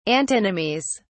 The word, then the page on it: antinomies: British English